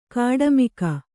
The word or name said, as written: ಕಾಡುಮಿಗ